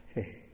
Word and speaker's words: He: Pronounced with a breath. He.